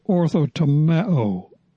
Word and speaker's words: orthotomeo: The verb, the Greek verb here, to handle the word of God accurately, is pronounced orthotomeo.